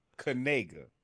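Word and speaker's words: Knigga: His name is Noah Kaniga. Kaniga. – K-N-I-G-G-A. K-N-I-G-G-A.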